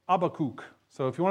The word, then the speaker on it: Habakkuk: That's how I pronounce it, Habakkuk. Okay, when I was a little kid and we learned the books of the Bible, I learned Habakkuk. Now, I know some of you may say Habakkuk.